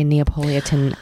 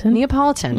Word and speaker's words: Neapolitan: And then I started in the fourth and final Elena Ferrante Neapolitan novel. I said that wrong. – Neapolitan.